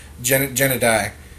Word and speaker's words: Gennady: So I read about this on Twitter last night before he suggested it to us, but and I mispronounced his first name earlier. It's Gennady.